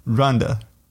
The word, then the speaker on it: Rwanda: Rwanda. Actually, I have to pronounce it in two syllables. Otherwise, Rwanda. I cannot say it. Rwanda. – Rwanda. Rwanda, like the woman's name.